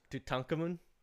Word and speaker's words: Tutankhamun: You mean Tutankhamun? That's the actual way to pronounce his name.